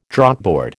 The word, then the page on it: Draughtboard: American English